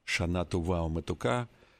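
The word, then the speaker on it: шана тува уметука (שנה טובה ומתוקה): хотя на иврите правильно говорить «шана тува уметука», чтобы этот Новый год был хорошим и сладким.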